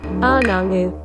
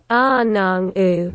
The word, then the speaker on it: Anangu: Et certains groupes aborigènes des déserts de l'Ouest et le centre de l'Australie se désignent eux-mêmes par le mot « anango » qui signifie « humain » ou « gens ». La véritable prononciation, je vais vous la diffuser tout de suite. – Anangu. Anangu.